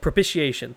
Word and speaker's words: propitiation: whom God put forward as a propitiation. I don't know how to say that word. by his blood, propitiation. That's how you say it.